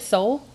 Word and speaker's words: Seoul: Seoul, North Korea? – That's South Korea. – Oh, okay. – We don't. Well, the only is style, that how you pronounce it? Seoul.